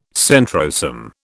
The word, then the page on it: centrosome: British English